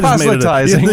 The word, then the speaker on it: proselytizing: proselytation, how do you say proselytizing? Thank you. I proselytizing. Made it yes.